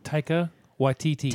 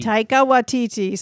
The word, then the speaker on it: Taika Waititi: Taika Waititi. – Taika Waititi.